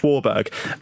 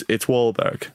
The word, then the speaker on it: Wahlberg: So I said, Mark Wahlberg, just Wahlberg. And then he went, it's Wahlberg. And I went, I just got it wrong.